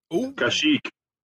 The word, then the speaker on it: Kashyyyk: Kashyyyk. – All right. Kashyyyk.